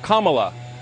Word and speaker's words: Kamala: Her name is pronounced comma, like the punctuation mark. Look, Kamala.